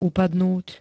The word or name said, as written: upadnúť